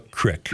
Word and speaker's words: creek: A single cow equipped with only determination and fairly good aim can, in a matter of hours, transform a perfect good creek into a crick. Hopefully this settles the debate of how the word is pronounced.